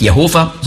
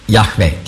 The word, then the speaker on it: Yahweh: Das ist also nicht Jehova, sondern Yahweh, die richtige Aussprache ist. Nicht Yahweh. Yahweh ist ein hörbares H. Auf Hebräisch sehr wichtig. Aber eben das hörbare H auch.